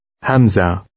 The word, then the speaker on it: hamza: Above the aleph is a new symbol called a hamza.